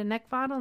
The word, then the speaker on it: Neckvatal: You can also find me on LinkedIn at Brenda Neckvatal, and my last name is spelled N as in Nancy, E C K, V as in Victor, A T A L.